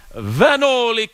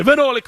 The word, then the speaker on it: Vanoli: Vanoli Cremona in Italy's. I believe that's, you have to pronounce it that way. Vanoli Cremona.